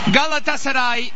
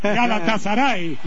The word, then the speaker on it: Galatasaray: Galatasaray. – Bueno. Así lo decimos nosotros también. Galatasaray.